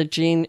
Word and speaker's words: Jeanne: Just type in my name, Jean Tiberio, and the Jean is spelt the French way, J-E-A-N-N-E.